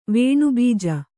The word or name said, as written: ವೇಣುಬೀಜ